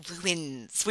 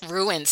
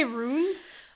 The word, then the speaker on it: ruins: The Ruins, which is a two-syllable word. I don't know if you were aware. – Ruins? – Ruins. So I'm a Long Island. Do you say runes?